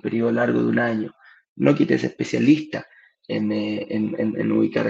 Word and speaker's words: Knockit: Había una pregunta en Instagram que me preguntaba cómo se llamaba la empresa y la empresa se llama Nokit, se escribe K-N-O-C-K-I-T, Nokit, es como de knock knock, cuando en inglés se, eh, cuando uno golpea la puerta, eh, Nokit, a eso se refiere.